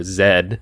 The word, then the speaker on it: Z: Or Zed, as it's pronounced up here.